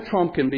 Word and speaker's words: Trumpkin: Did you know I'm a Trumpkin? It rhymes with pumpkin. I guess he likes that. I'm a Trumpkin because I like right-wing things.